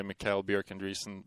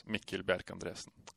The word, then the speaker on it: Mikkel Bjerch-Andresen: So I kind of Americanize it, say Mikkel Bjerch-Andresen, but the Norwegian pronunciation is Mikkel Bjerch-Andresen. – Oh.